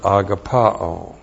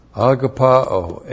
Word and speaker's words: agapao: And then we have the verb agapao. We're going to have this a lot in the next two chapters. Agapao, A-G-A-P-A, long O. Agapao.